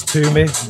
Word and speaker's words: Toomey: Yeah, so my original name is Toomey, with T-O-O-M-E-Y.